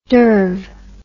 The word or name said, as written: derv